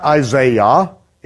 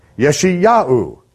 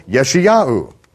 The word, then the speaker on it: Yeshayahu: It's not Isaiah, it's Yeshayahu. But you see, Isaiah, I-A-H, is Yah. That's how it is brought over in that language, but it is Yeshayahu.